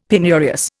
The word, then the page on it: penurious: Indian